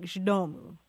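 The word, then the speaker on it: Z domu: It's Z space D-O-M-U. And it's pronounced something like Zdomu.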